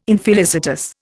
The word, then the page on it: infelicitous: Indian